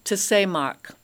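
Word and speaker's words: tesemach (צמח): The Hebrew word for branch is neat because it's tesemach is the pronunciation.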